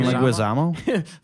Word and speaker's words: Leguizamo: That was played by John Leguizamo. Is that how you say it? – John Leguizamo.